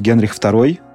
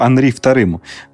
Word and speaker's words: Анри II: Э, во Франции у нас э, правит король Генрих II. Ну, как Генрих, это у нас так принято произносить его имя. Все-таки правильнее будет называть его Анри II.